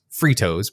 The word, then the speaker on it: Fritos: In his right hand, it's pronounced Fritos, by the way.